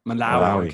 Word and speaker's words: Malawi: Malawi.